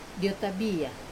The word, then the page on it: otabia: wamesa